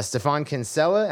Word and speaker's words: Stephan Kinsella: And I'm very excited because today we have an absolutely fantastic guest and, and an expert on this subject, uh, Stephan Kinsella.